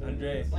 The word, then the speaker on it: Andrade: His name is going to be Andrade. That's it. – Oh, how about say his name correctly. Say his name correctly. – Oh, yeah. – Andrade. – Like 30 seconds. – That's his new name.